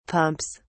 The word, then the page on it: pumps: British English